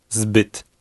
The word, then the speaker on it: zbyt: Listen and repeat number 4, paying attention to the sound of the short Polish Y. – Zbyt.